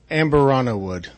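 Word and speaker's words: Amburana: Amberana wood. – Amberana wood. And can you walk us through? – Yeah, absolutely. So this is a 7-year barrel. It is 128 proof. It is finished with Amberana wood, and the flavors pull a lot of Christmassy gingerbread, toffee notes, just really making the flavor quite unique to our regular barrels.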